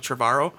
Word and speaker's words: Trevorrow: Uh, how do you pronounce it? Trevorrow. Um, tomorrow. Yeah, like tomorrow, but tomorrow.